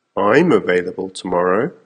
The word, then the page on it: I'm available tomorrow: American English